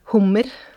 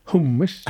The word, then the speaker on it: hummer: Hummer. – Hummer. – How would you spell it? – H-U-M-M-E-R.